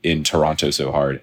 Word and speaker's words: Toronto: No, actually, I'm going to Toronto tomorrow. – Ooh, Toronto, or as I've been told to pronounce, Toronto. Yeah. I learned the hard way because I've always said... There's no second T.